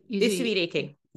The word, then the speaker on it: Usui Reiki: So Usui Reiki, you're a Usui Reiki, am I saying that right? – Usui Reiki. Yeah, that's right.